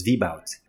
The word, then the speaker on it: Vbout: So it's Vbout. So, and it's V-B-O-U-T. I'm sorry if I pronounced it wrong. – No, no, that's correct.